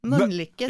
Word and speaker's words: Mölnlycke: Du uttalar Mölnlycke väldigt konstigt. Det är som med Mölndal, att ni utifrån kan vara... Säg Mölnlycke. – Mölnlycke. Du liksom uttalar för mycket L. Mölnlycke säger man mer.